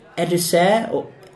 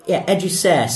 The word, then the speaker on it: educere: And then the other root word where it comes from is, I think you would pronounce it educere, educere, C-E-R-E, educere, which means to lead out.